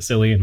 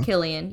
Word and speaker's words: Cillian: I think I heard Christopher Nolan say Killian, and I've been pronouncing it Cillian my entire life, so. – Is he of Irish descent? – I think so. – Yeah. – Then it is Killian, yes.